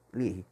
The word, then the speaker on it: Leahy: Como se escreve sem o, o i ou o e no final. L-E-A-H-Y, Lear.